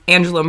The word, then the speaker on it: Angela: Well, so Josie tweeted at us, said, hey Hellbent Pod, noticed you pronounce Angela Merkel's first name like in Spanish. In German, it has a hard G, like in quote, guys.